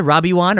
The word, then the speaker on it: Robiewon: I just do the spelling, R-O-B-I-E-W-O-N. – Yes, R-O-B-I-E-W-O-N. Robiwan. How do you pronounce it, Robiwan or Robiwan? – Oh, you have to rhyme it with Obi-Wan. – Yeah, so it's Robiwan.